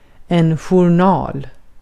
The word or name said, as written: journal